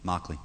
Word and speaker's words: Mauchly: Mockley. – Spell it. – M-A-U-C-H-L-Y.